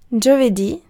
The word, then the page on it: torsdag: /ˈtuːrsdɑːɡ/; [ˈtʰuːʂd̥ɑːɡ]; /ˈtuːrsda/; [ˈtʰuːʂd̥a]